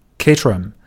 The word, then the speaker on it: Caterham: 那么这个品牌呢，它叫做呃 Caterham，或者英国人喜欢读成 Caterham。